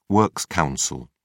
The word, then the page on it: works council: British English